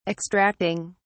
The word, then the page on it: extracting: British English